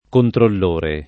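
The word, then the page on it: controllore: italiano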